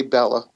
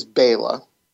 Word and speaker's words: Bela: Now, I always say Bella, but it is Bela.